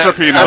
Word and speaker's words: Rapinoe: I think it's Rapinoe. It's Rapinoe.